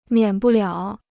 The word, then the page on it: 免不了: miǎn bù liǎo